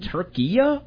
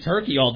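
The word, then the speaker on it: Türkiye: I was saying Turkey all day yesterday, and then I felt like, a moron when I heard everybody else say it. Turkey-a, I think, is what the pronunciation is now.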